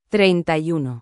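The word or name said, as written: treinta y uno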